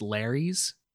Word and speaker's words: Lares: So the Lares, now that's it's Latin. So a single one would be a lar. Some of the sources I read said that they were pronounced Larrys.